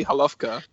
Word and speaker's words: Holowka: Holoka. I think it's pronounced, is it? – It's probably Holovka because it's a Polish name.